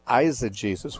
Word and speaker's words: eisegesis: The opposite is eisegesis, which is spelled E-I-S-E-G-E-S-I-S, and that eis is the Greek word for into.